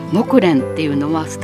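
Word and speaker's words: 木蘭: でサラサ木蓮を調べた時には、木の蓮と書いてそれで木蓮、あれと思って、今まで何気に見てきたっていう木蓮っていう字ですけども、木の蘭と書いても木蓮っていうのはスターダストレビューの曲木蓮の涙でしょ。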